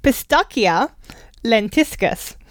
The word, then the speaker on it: Pistacia lentiscus: I'm going to say it's a hard C. Pistacia lentiscus.